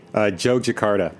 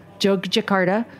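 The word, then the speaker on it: Yogyakarta: Jogjakarta. – Jogjakarta.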